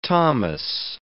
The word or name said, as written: Thomas